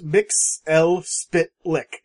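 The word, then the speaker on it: Mxyzptlk: Actually, if you watch the animated, when he, the animated Superman TV show, it's pronounced Mix L Spitlick.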